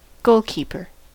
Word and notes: goalkeeper: American English

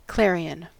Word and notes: clarion: American English

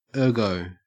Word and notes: ergo: Australian English

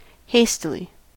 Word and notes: hastily: American English

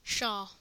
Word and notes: pshaw: American English

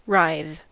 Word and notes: writhe: American English

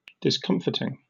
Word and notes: discomfiting: British English

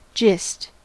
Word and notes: gist: American English

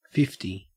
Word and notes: fifty: Australian English

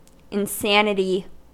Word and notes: insanity: American English

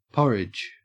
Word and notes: porridge: Australian English

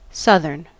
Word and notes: southern: American English

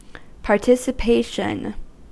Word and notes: participation: American English

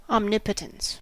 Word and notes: omnipotence: American English

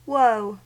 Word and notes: woe: American English

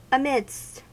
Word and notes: amidst: American English

